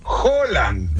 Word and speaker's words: Haaland: que no se dice Erling Haaland, se dice Erling Haaland.